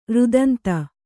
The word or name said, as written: ರುದಂತಿ